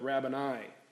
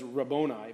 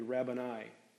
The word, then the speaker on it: rabboni: So it's like rabbi with an O-N in the middle of it, rabbani. Somebody might pronounce it as rabboni, but I think it's more accurately rabbani.